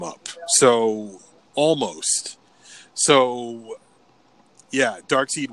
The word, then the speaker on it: Darkseid: Well, it's pronounced Darkseid because the E and the I. Einstein mm-hmm. and Stein.